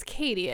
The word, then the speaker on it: Katee: Or if I get, I hate being called Kate because that's not my name. My name is Katie.